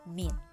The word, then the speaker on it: mean: Untuk data tunggal yang pertama adalah rata-rata hitung atau mean ditulisnya mean ya dibacanya min